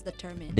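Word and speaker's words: determine: So it's easy to think that what if the last two syllables are pronounced mine, because when it's an individual word, then it's pronounced mine, right? – Okay. Yep. – However, when mine is attached in this word, then it becomes determined.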